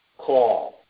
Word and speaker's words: qowl: This word voice is the Hebrew word call. Pronounced just like you would say, I'm going to make a phone call. It's spelled Q-O-W-L, but it's pronounced just like the English word call.